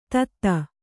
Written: ಥತ್

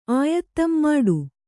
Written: ಆಯತ್ತಂ ಮಾಡು

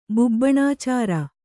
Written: ಬುಬ್ಬುಣಾಚಾರ

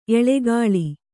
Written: ಎಳಕುಳಿ

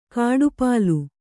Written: ಕಡುಪೊಲ್ಲ

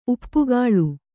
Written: ಉಪ್ಪುಗಾಳು

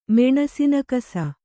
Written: ಮೆಣಸಿನಕಸ

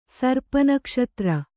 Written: ಸರ್ಪನಕ್ಷತ್ರ

